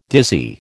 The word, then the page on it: dizzy: American English